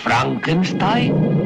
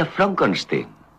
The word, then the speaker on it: Frankenstein: Doctor Frankenstein? Frankenstein. ¿Me toma el pelo? No, se pronuncia Frankenstein.